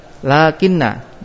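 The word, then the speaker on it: lakin (لكن): Nah, maka bagaimana kita bacanya? Baca lakin, lakinna. Enggak boleh dibaca pendek. Kalau baca pendek salah. Nah, dibaca panjang.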